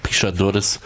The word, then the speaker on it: Pixadores: Pixadores heißt übrigens der Film, oder er wird anders ausgesprochen, Pixadores. – Genau, irgendwo. Also ich krieg's auch nicht so gut auf Portugiesisch hin.